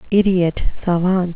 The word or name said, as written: idiot savant